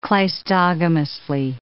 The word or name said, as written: cleistogamously